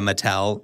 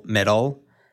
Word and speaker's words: Mattel: I was hanging out with friends this weekend, and first of all, one of them said instead of Mattel, middle. She was like, apparently there's a bunch of middle in the works. And we were like, wait, what? – That's how she pronounced it.